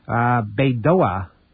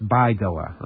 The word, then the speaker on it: Baidoa: He visited, Baidoa on Tuesday, a day before that city was secured by foreign troops. Actually, it's pronounced Baidoa, I'm sorry.